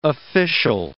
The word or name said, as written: official